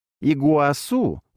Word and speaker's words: Игуасу: Игуасу, ударение на последний слог, переводится как «большая вода».